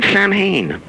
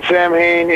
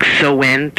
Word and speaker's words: Samhain: And does it bug you when people pronounce Samhain instead of, like, So-en? There's a proper way to pronounce it, isn't there? Does that bug you? – Yeah, it's actually, there's a few pronunciations, but the pronunciation I use on the records is Samhain. It doesn't bug me because it's a Celtic word, and you really can't expect most Americans who can only barely speak English to learn how to pronounce anything Celtic or anything else.